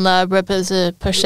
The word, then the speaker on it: La Roche-Posay: Well, the sunscreen is that, how do you pronounce that brand? La Roche-Posay. – La Roche-Posay. Great sunscreen. Well, I mean, I say La Roche-Posay. Am I saying it wrong? – No, I just never say it.